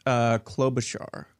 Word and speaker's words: Klobuchar: Klobuchar. – Klobuchar.